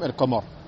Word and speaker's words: القمر: le لام se prononce clairement سميت قمريه تشبيها لها بلام القمر القمر je dis bien القمر